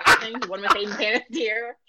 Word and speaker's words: Panettiere: There are two names where I feel extremely douchey when I pronounce them correctly, and that's Hayden Panettiere, and it's Guy Fieri. and I know those are the correct pronunciations because they've said them.